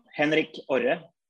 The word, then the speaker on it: Henrik Orr: So, Henrik, would you like to say this properly for me so I don't feel horrible? – I will. Henrik Orr. – Orr. Yeah, close, better? – Almost.